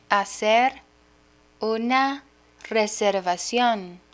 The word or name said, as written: hacer una reservacion